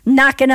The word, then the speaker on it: Knocking: Before that, the silent K, the sneaky K, Knocking on Heaven's Door by Bob Dylan.